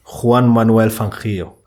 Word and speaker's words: Juan Manuel Fangio: Kannst du das bitte nochmal aussprechen? – Juan Manuel Fangio.